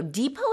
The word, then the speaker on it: depot: Depot is spelled DEPOT. We don't pronounce the T at the end, so it's pronounced depot.